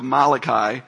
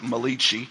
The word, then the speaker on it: Malachi: So 1 Corinthians, if you want to just flip your finger over to the book of Malachi, if you're Italian, you probably pronounce it Malachi. But it's Malachi.